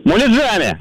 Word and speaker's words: муляжами: Ударение в данном слове всегда ставится только на окончании, потому что правильно говорить, конечно же, еще раз как? – Муляжами.